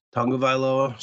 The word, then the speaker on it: Tagovailoa: They did it without Tua Tagovailoa, which, okay, great. Tagovailoa, sorry.